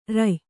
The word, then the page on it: ರೂಯಿ: rūyi; Kannada